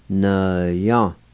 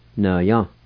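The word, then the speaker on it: nvya: N ya. N ya. N ya.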